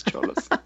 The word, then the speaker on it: Xolos: You know, the first thing that is often raised is the issue of Xolos. Did I pronounce that correct? Why don't you correct that? – I've heard it pronounced Cholos, like C-H. – Fuck, that's right, that is Cholos.